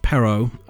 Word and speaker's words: Perrault: And it comes from a book bought by Charles, I'm going to say that's pronounced Perrault, 1697, in a book called Histoire au Conte de Temps Passé. – That was good.